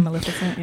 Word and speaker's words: Maleficent: Yeah, Maleficent.